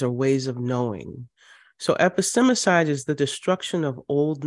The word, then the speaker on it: epistemicide: And I'm thinking about a word that you actually introduced me to, epistemicide. Did I say that right? – Mm-hmm. You sure did.